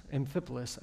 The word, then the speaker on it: Amphipolis: After they had passed through Amphipolis, Amphipolis, I actually said that correctly earlier, and Apollonia, they came to Thessalonica where there was a Jewish synagogue.